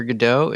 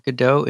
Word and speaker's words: Godot: And apparently in the French pronunciation of Godot is more like Godot. The first syllable is emphasized, so the God part.